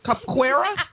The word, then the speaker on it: capoeira: Right, okay, that's what I was going to say, because it looks a little bit like a cross between capoeira and like a karate or something. – I would love to see it, first of all. – Capoeira? Did you just drop that so well pronounced? – That's right.